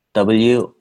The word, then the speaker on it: Woori: Can you spell that? – WOORI. – Woori Bank, okay. – Woori Bank. That in Korean, that means our bank.